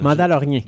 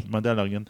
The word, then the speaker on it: Mandalorian: Mandalorian. Mandalorian. Ouais, je sais pas pourquoi je rajoute une syllabe.